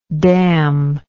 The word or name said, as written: damner